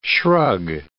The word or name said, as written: shrug